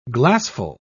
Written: glassful

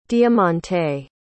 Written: diamanté

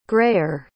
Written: grayer

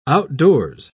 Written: outdoors

